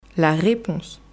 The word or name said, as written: la reponse